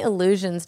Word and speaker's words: allusions: He made many illusions. How do you say it? – So illusions. – Yeah, not like it's an illusion. – Illusions. – Illusions. He made many allusions to how large his ego was.